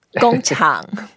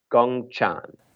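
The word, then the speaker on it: Gong chang (工厂): Since factory is so important to everyone's life and also a very important part of Chinese economy, let's learn how to say it. – Okay. – Gong chang. – Gong chang. – Gong chang. – Gong chang. – That's it. So gong is the first word. It means work.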